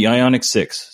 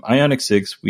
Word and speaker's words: Ioniq: But we have the Ioniq 6. Said it right this time. Ioniq 6.